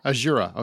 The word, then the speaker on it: Azura: Well, in the film Azura Sky, did I say her name right? Azura? – Azura.